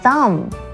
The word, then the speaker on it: thumb: 再来很多字，其实呢它是没有发其中的字母的音，比方像是大拇指 thumb, OK, 后面最后的 B 是不发音的哦， thumb 的拼法是 T-H-U-M-B, 可是 B 是不发音的，所以是念 thumb。